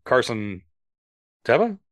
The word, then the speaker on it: Carson Teva: And so the fact that like Tim Meadows' character, Carson Teva? Am I saying his name wrong? What's the TIE fighter pilot that they called Blue? – Yeah, you're right.